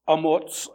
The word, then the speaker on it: Amoz: The vision of Isaiah, the son of Amoz, that's how it's pronounced in the Hebrew, Amoz, concerning Judah and Jerusalem, which he saw during the reigns of Uzziah, Jotham, Ahaz, and Hezekiah, kings of Judah.